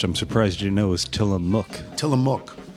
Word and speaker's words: Tillamook: Well, they don't tell you how to pronounce it. It just looked to me like Willamook. Yeah, Tillamook. Looked like a, looked like a mook. Like Willamette. I thought it was I thought it was Willamette when right.